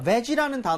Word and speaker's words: veggie: veggie 할 때 V가 입술 안 붙는 거는 기본이지만 그것보다요. 저는 G 할 때 입 모양을 좀 모았으면 좋겠어요. 안 그러면 veggie 이렇게 하시는 분들이 있어요.